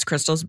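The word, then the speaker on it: Krystle's: So it's Crystal's Book Club Crystal's Book Club.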